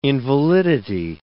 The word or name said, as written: invalidity